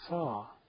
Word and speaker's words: Sau: As you breathe out, you repeat the word Saw, as in hammer and saw, only it's spelled S-A-U.